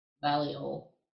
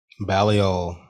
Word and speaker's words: Balliol: Balliol. – Balliol.